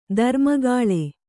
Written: ಧರ್ಮಗಾಳೆ

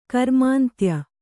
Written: ಕರ್ಮಾಂತ್ಯ

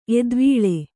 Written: ಎತ್ತುವಳಿ

